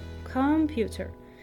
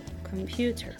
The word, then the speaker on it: computer: For example, in the word computer, we would stress the first syllable, computer. But the correct pronunciation is to stress the second syllable, computer.